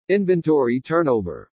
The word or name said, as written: INVENTORY TURNOVER